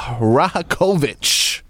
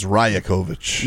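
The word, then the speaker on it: Rajaković: Let's start off with numero uno, Darko Rajakovic. Did I pronounce that correctly? – I think it's Rajakovic.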